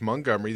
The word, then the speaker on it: Montgomery: Pronounced it right, folks. Montgomery. The T is silent.